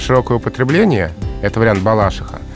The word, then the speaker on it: Балашиха: И это вариант «балашиха». А, и интересно, что словари русского языка, словари, адресованные работникам СМИ, а, поддерживают местный вариант. То есть ударение «балашиха» зафиксировано в словарях. И хотя оно ну, непривычно многим носителям языка, но особенно перед микрофоном надо говорить именно так.